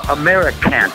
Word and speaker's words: Americant: Only part of it, but when you see the title written on the cover, it's easy to get the meaning, but how do you actually pronounce it? – Americant. – So you don't say KKK, you say cunt or cunt. – No, it's like America can't keep doing the shit that they're doing and expect to survive as a democracy or a country for that matter.